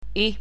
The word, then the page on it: i: latvian